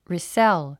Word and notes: resell: rìsél; リィセェゥ

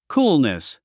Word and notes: coolness: kúːlnis; クールネス

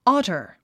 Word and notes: otter: ɑ’tə r; アタァ

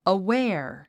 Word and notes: aware: əwéə r; アウェア

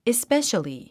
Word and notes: especially: ispéʃəli; イスペェシャリィ

